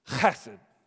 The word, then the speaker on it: Khesed: Chesed is how you say it. If you want to write it down, if any of you are note takers, it'd be K-H, that gives you that phlegm sound. K-H-E-S-E-D. That's the word that's translated in your English Bibles, here in the ESV, as steadfast love.